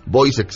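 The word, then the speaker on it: Woyzeck: Boisek, se escribe Woyzek.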